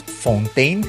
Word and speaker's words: Fountain: Les recomendamos Fontaine, se escribe Fountain,